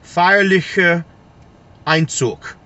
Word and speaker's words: Feierlicher Einzug: And the name of the piece is Feierlicher Einzug. Feierlicher Einzug. So it's F-E-I-E-R-L-I-C-H-E-R. And then the second word is spelled E-I-N-Z-U-G.